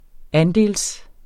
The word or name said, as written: andel